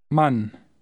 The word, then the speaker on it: Mann: Mann. – Repeat it aloud after him. Always repeat aloud after the speaker. – Mann. Mann. – Notice that the letter A is pronounced differently in German than in English.